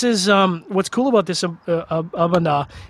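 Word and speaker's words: A'bunadh: This is the Aberlura Bunda. Or Abuna, sorry, terrible, terrible Gaelic pronunciation there.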